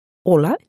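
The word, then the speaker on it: hola: Хотя поначалу некоторые звуки могут вызывать трудности, например, буква «h» в испанском языке вообще не произносится. «Ола» читается как «ола», «амбре» как «амбре».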